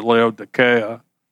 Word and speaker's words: Laodicea: That's actually the way you're supposed to pronounce it, Laodicea.